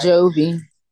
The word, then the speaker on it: Jovi: Like, bitch, I'm gonna help you, not just watch your fucking kid while you do the same shit you was grilling Javi, whatever. I never say his name right. – Jovi.